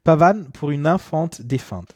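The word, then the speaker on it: Pavane pour une infante défunte: Wir hören von Maurice Ravel, Pavane pour une Infant Defunt, habe ich das richtig gesagt? Ja, kannst du das nochmal? – Pavan pour une Infant Defunt. – Defunt, ja.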